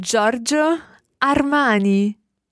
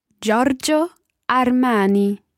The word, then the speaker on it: Giorgio Armani: Giorgio Armani. Giorgio Armani. – We've got a G I O in there, which is not Giorgio or anything like that. It's just straight into Giorgio. – Giorgio. Giorgio Armani.